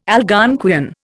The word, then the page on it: Algonquian: Indian